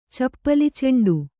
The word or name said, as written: ಚಪ್ಪಲಿಚೆಂಡು